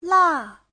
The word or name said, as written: la4